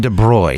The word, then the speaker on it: de Broglie: Yeah, so okay, so Domaine de Broglie. – De Broglie, yes.